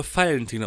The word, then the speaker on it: Valentin: In Bayern wird der Name Valentin ausgesprochen. Mit Vogel-V.